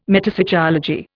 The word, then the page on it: metapsychology: Indian